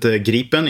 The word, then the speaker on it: Gripen: I would pronounce it Gripen in Swedish, but Gripen is fine as well.